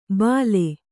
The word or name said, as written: ಭಲೆ